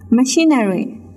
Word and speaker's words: machinery: The next word لغت بعدی machinery machinery M A C H I N E R Y machinery machinery. And this word the stress is on the second syllable. It means on CHI machinery.